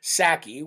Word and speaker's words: Psaki: Well, the deputy press secretary, who's second in charge to Jen Psaki with a silent P, just like the silent P in president,